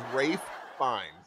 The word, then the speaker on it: Ralph Fiennes: Isn't it just Ralph Fiennes, if I'm not mistaken? – It is Rafe fine.